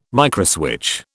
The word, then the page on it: microswitch: British English